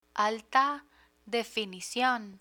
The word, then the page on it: alta definicion: spanish